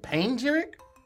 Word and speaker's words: panegyric: You're leaving out a syllable. – Oh, shit. Wait, is it panegyric? Panegyric. – No. – Mm-mm. – Panegyric. – That's correct.